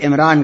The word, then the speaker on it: عمران: عمران بتایا گیا ہے قرآن اسی کا تلفظ عمران کرتا ہے